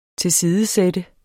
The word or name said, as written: TILSIDESAT